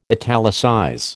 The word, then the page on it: italicize: American English